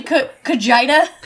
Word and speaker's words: Cote: Now, pronouncing your last name Cotija? – Yeah, Cotija. It's actually Kajida.